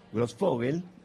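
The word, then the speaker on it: Grosfoguel: Así es, y estamos acá, en la mesa, en la Feria del Libro, con el sociólogo Ramón Grossfogel, ¿lo dije bien? – Muy bien.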